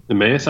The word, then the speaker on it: Emmaus: Is it Emmaus? How do you pronounce his name? – Emmaus. – I thought he was from Allentown. – Nope. Emmaus.